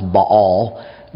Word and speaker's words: Baal: Now, the legend of Baal, probably should be pronounced Baal, Baal Zebul, Lord and Master.